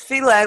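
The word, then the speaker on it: filé: It's filet, like filet gumbo? – Filet gumbo, yeah.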